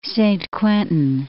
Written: Saint-Quentin

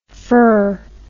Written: fer

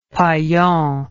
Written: paillon

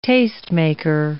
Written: tastemaker

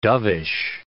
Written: dovish

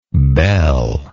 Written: beal

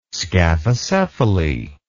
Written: scaphocephaly

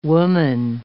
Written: woman

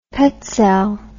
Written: petsel

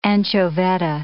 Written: anchoveta

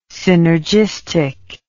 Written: synergistic

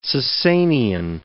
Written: Sassanian

